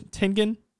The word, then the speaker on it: Tengen: How do you say his Tengen? – Yeah.